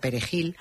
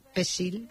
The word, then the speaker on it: Perejil: Los soldados de Trujillo detectaban a los que había que matar haciéndoles pronunciar la palabra Perejil, porque aunque supieran castellano, esa palabra no sabían pronunciarla. Y si decían, en vez de Perejil, decían Pesil, pues, que es como lo llamaban, sí, sí.